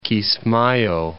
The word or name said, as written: Kismaayo